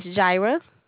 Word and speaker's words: Jara: Yes, Jaira. – Could you spell that? – J-A-R-A.